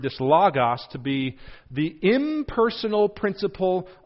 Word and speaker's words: logos: That Greek word, there is logos, L-O-G-O-S. You could pronounce it logos, logos.